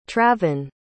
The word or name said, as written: travon